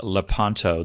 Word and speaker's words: Lepanto: And people were very quick to point out, I believe that's pronounced Lepanto, the Battle of Lepanto in 1571, in a battle with the Ottoman Empire.